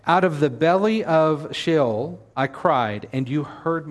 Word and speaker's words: Sheol: It's a transliteration of a Hebrew word, which means they just simply spelled it in English letters, but the word is Sheol. And that's how it's pronounced in Hebrew as well.